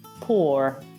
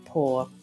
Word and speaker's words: pour: si dice pour, con una R marcata se è un inglese americano, invece una R impercettibile se è British English, quindi diventerebbe pour. Come si scrive? P-O-U-R, so pour.